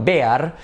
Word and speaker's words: bear: Ok, number 68, how do you say oso, el clásico oso, escrito bear. Aquí la clave está en que hay que hacer como un diptongo.